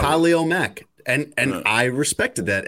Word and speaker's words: Khalil: But like the running back, for example, Herbert, his name is like, typically when you see that name, you say Khalil. I think that's like, if there's a common pronunciation of that, of K-H-A-L-I-L, it's probably Khalil.